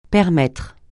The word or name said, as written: permettre